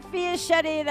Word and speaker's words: Fiaschetti: This is Bernadette Fiaschetti, that rhymes with spaghetti, with Jerry Caldwell and Marie Early.